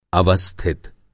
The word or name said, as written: अवस्थित